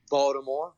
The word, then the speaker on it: Baltimore: I've been living well, living in the purple and black, you know, in the beautiful city of Baltimore right now, or as we would say, Baltimore, because we don't pronounce that T, you know.